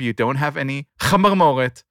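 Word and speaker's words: chamarmoret (חמרמורת): The term is chamarmoret, which is pronounced chamarmoret and not chamarmeret, as was pronounced on the podcast.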